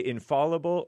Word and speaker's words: infallible: Would you please pronounce it correctly? – What? – You can say infallible or infallible. – F-A-L-L, right? – Right. – Infallible. – Or infallible. I don't know which one is more correct.